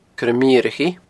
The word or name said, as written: coiremeurachaidh